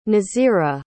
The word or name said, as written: nazirah